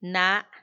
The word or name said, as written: naa